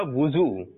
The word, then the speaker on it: وضو: صحیح تلفظ ہے اس کا وضو. واؤ پر کیا ہے؟ پیش ہے, تو اس کو دھرا لیجئے. کیا کرنا ہے؟ وضو. کیا کرنا ہے؟ کیا کرنا ہے؟ جی درست تلفظ ہے وضو, نہ کہ وضو.